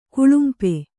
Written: ಕುಳುಂಪೆ

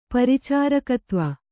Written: ಪರಿಚಾರಕತ್ವ

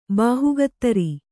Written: ಬೇಹಕ್ಕುದಾರ

